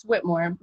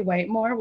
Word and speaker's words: Whitmore: Oh my god, yeah, it's Whitmore. A lot of people say Whitmore, which is really totally on topic today.